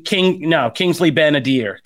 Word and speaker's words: Kingsley Ben-Adir: Jackson, Olivia Colman, Amelia Clark, like Ben Fadir. I always kind of like mess up his name. I don't want to butcher his name. Ben Mendelsohn, Kingsley Ben-Adir. Kingsley Ben-Adir, who plays the villain in it.